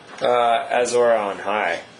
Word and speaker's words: Azor Ahai: Azor Ahai.